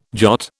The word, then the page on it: jot: British English